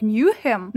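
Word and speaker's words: Nahum: Во-первых, владельца фермы в книге зовут Нейхем, ну, у нас его так перевели, по крайней мере, на английском он скорее произносится как Ньюхем, но это анаграмма для слова human.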